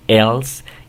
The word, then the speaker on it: else: Cuando tengas una palabra de este tipo, esta E que está aquí al final no se va a pronunciar, ¿ok? Nunca se va a pronunciar. Ese es el primer principio que debes saber. Aquí esta palabra se pronuncia else y acá se pronuncia table.